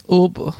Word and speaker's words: Uber: Heißt da, das heißt Uber, da das ist es anders ausgesprochen. Wie wird es ausgesprochen? Ober. So, Muss ein bisschen Wein dabei. Ober. Und ähm, dann dachte ich, hey, ich bin cool, ich benutze jetzt Uber und bin mit meinen beiden Kumpels, ähm, kein Problem.